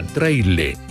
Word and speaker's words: traile: Hay quienes lo asocian al sonido de un ave que en Mapudungún se pronuncia traile, y hay quienes lo asocian a la palabra chiri, que en quechua significa frío o nieve.